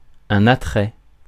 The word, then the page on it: attrait: France; [a.tʁɛ]